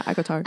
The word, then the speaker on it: ACOTAR: Remember in the very beginning when you first started reading, ACOTAR, I don't even know how to say it. Is that how you say it? – Yeah. ACOTAR.